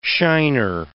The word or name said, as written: shiner